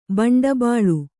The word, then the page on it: ಭಂಡಬಾಳು: bhaṇḍa bāḷu